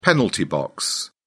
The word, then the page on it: penalty box: British English